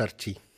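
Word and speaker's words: RT: ну или правильно говорить сейчас Арти. – Да, так правильно.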